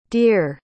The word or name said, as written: deere